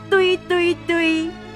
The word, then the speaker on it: 追追追: 追追追，然后闽南语的发音叫做堆堆堆，哎，这个苏打绿还翻唱过。